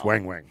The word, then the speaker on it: Wang Wang: Well, I thought every time I talk to you, you do give our lovely Wong Wong a little bit of a hard time, so I thought I'd bring him with me. – Say it right, it's Wang Wang.